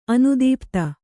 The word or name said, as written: ಅನುದೀಪ್ತ